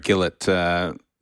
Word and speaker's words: Gillett: What a fabulous feature piece that was that ultimately got to air on Jared Gillett. – Rhymes with Philip.